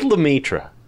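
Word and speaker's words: Lemaître: Go with Lemaitre.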